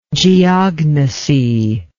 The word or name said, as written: geognostically